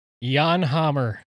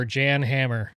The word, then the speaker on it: Jan Hammer: Jan Hammer, or Jan Hammer, if you want to just pronounce it the way it's written.